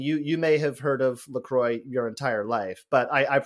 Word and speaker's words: LaCroix: how is LaCroix pronounced? And the answer is LaCroix. It rhymes with enjoy, which is a delightful little bit of marketing copy. Intentionally, it's not La Croix. It is LaCroix, American sparkling water.